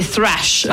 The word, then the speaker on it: thrash: Alors thrash en anglais, ça veut dire poubelle, détritus, mm. hein, enfin. – Alors là, attention, c'est thrash metal. – C'est thrash. – Désolé, je le prononce très mal.